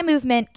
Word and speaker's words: MVMT: Reminder that Movement is spelled MVMT. Okay, so see why Movement keeps on growing.